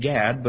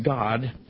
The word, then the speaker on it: Gad: Of the tribe of," we pronounce it Gad, but it's actually pronounced God, the tribe of God in Hebrew, 12,000 were sealed.